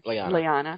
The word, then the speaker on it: Lyanna: Lyanna. How do you say it? – Lyanna.